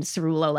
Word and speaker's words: Cerullo: And in that case, I've done Cerullo like cerulean blue.